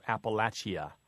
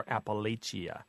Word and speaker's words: Appalachia: For example, some of the pronunciations think that the last syllable shouldn't be a schwa, that mm-hmm. it should be ia, so Appalachia or Appalachia. And there's a SH pronunciation versus a CH, so Appalachia.